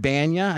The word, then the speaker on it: Bana: And the guy was Eric Bana.